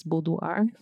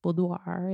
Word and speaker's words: boudoir: But most importantly, boudoir, the word boudoir itself, some people kind of pronounce it in a different way, but this is how I do boudoir. If somebody else knows how to say it differently, please tell me. But it comes from the French and it means bedroom, intimate bedroom.